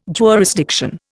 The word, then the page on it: jurisdiction: Indian